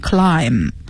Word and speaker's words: climb: 那英文里边还有一个单词也是这个读音规则，叫 climb，爬 climb 也是 b 不发音 climb。